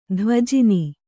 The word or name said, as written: ಧ್ವಜಿನಿ